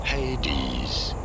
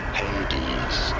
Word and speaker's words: Hades: How do you go about pronouncing it? Hades. You do want to stress on the first syllable, the he syllable. Hades.